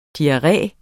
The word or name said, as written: diarré